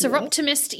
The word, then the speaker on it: Soroptimist: You are the president, and I'm going to butcher this, of the Soroptimist, is that right? Yes. Soroptimist International, yes.